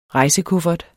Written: rejsekuffert

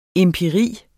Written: empiri

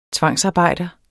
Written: tvangsarbejder

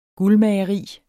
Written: guldmageri

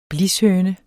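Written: BLISHØNE

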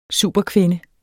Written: superkvinde

